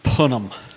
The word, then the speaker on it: punim: The Yiddish word of the day is punim.